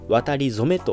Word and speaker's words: 渡り初め: 渡り始めと書いて渡り初めと読みます書き初めと一緒ですね